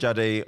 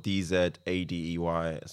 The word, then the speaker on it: Dzadey: My surname is spelled Dzadey. So just mainly on Instagram. – I was going to say, you need to spell it because man said Dzadey like it was J-A-D-E.